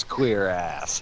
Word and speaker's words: cuirass: Sparks flying through the air off his queer ass. – It's how it's pronounced, damn it.